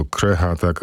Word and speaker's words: Krecha: Dotychczas boomersi znali tylko powiedzenie kupować na krechę, od dziś możemy grać na krechę, bo krecha, tak wymawia się nazwę tego zespołu.